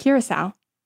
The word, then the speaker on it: Curaçao: Curaçao.